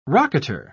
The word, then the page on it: rocketer: British English